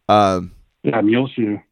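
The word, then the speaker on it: Muleshoe: Never even heard of, what is it, Mole Shoe? Is that how you say it? Like, Mule Shoe.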